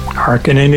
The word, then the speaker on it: Harkonnen: And based on they were geologically when they were on Earth, that's how their name would be pronounced. So, yeah, as far as Frank Herbert is concerned, and as far as I'm concerned, it's always going to be Harkonnen. – Harkonnen it is.